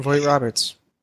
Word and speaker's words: Vogt-Roberts: I'd give credit to Jordan uh, Vogt-Roberts. Am I pronouncing that right? – Yeah, Jordan Vogt-Roberts.